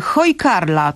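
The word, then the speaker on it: hákarl: Chodzi ci o hojkarla? – O właśnie, hojkarla, to ahoj Karla, tak? Czyli witaj Karlo w języku żeglarskim. – Ahoj, tak. Hojkarl natomiast pisze się hakarl z takim akcentem na a.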